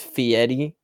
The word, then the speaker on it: Fieri: It's actually pronounced Fieri, okay? – Fieri.